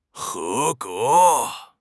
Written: he2 ge2

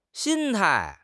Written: 心态

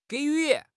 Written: 给予

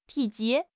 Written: 体积

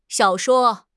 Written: xiao3 shuo1

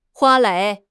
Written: 花蕾

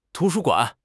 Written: tu2 shu1 guan3

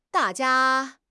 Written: da4 jia1

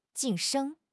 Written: 晋升

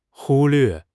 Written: 忽略

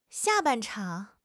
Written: xia4 ban4 chang3

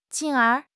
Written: 进而

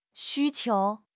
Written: 需求

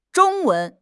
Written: Zhong1 wen2

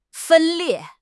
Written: 分裂

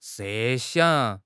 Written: 设想

